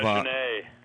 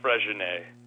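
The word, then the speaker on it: Freixenet: Freginet. – What? How do you say it again? – I think it's Freginet. – Weird word. – The X isn't an X. It's a weird sound in that language.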